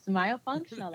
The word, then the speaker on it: myofunctional: So your therapy, and I hope I pronounce this correctly, is myofunctional? – Yes, myofunctional. That was perfect.